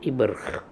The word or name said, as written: Ibrkh